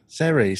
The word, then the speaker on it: Ceri: How do you say this? – Seri. – Seri, C-E-R-I. Seri.